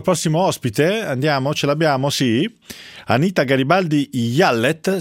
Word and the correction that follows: Jallet: si pronuncia così, buonasera. – Si pronuncia Giallè. – Giallè, mi scusi.